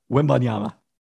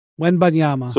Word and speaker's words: Wembanyama: But Victor Wembanyama. Did I say that right, Pelton? – Wembanyama. – Wembanyama.